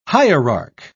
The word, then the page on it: hierarch: British English